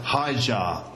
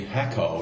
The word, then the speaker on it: Hacer: Her name is Her name is, well, it's spelled H-A-C-E-R, and it's pronounced Hijar. Hijar. Can I remember that? Because it's like, imagine a jar of, like, coffee, and you say, hi, hi to the jar. So, Hijar.